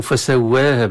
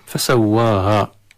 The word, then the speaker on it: فسواها: لأن يعني سوى من سويت يسوي، فأصلها يائية، فلهذا في بعض القراءات تقرأ فسويها بالإمالة أو فسواها بالإمالة الصغرى، ولكن أغلب القراء على الألف تنطق كألف فسواها. والحذر في هذه الآية من تفخيم الواو مع الألف فسواها هكذا، أو التقليل من الواو والألف فسواها كما في الروايات الأخرى، تقرأ إذن فسواها.